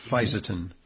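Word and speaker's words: fisetin: correct me if I'm pronouncing it right, facetin, or is it physetin? – Yeah, I think it's, but I think both, physetin, I think it's what I call it.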